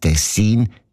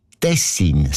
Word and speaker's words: Tessin: Aber der SRF-1-Hörerin Regula Stern-Grieser von Locarno ist aufgefallen, dass gewisse Leute statt Tessin Tessin sagen, also Betonung auf dem E mit einem kurzen I.